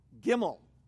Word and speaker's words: Gimel: Gimel. I like that word, Gimel. It's kind of like Gimli.